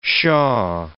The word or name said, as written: shaw